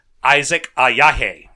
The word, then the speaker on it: Isaac Ajayi: So um, he's a Nigerian, and his name is Isaac Ajayi, right? But African players. Success. Yeah. Uh, hey, I actually pronounced it correctly.